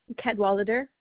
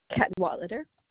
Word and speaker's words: Cadwallader: Cadwallader, 16001. – 160001. Okay, could you spell your last name for me? – Cadwallader.